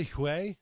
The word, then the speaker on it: Huawei: How do you pronounce it? UI? – I say Huawei. – Huawei. – But it could be UI.